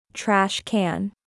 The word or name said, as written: Trash can